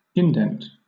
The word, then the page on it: indent: British English